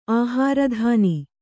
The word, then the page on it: ಆಹಾರದಾನ: Kannada